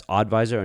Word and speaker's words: Audvisor: Again, that's Oddvisor, and it's spelled Audvisor.